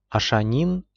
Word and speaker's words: Ашанин: Ашанин с ударением на последнем слоге.